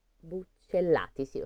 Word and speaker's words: buccellati: Disse a sfortuna, con questi due pani buccellati, buccellati, sì, lo sto pronunciando giusto, c'è cioè due L, Va alla riva del mare e chiama la mia sorte, così.